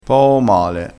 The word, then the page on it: poo male: dialettale